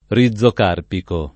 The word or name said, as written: rizocarpico